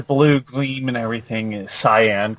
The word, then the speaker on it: Cyan: It's Cyan. – Cyan? – Yes. Yeah, like Scion is the type of car.